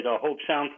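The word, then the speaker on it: Hobe Sound: Let's go to, is it Hobesound, Florida? Did I say that right? Hobesound? – Yes, you did. Hobesound, Florida.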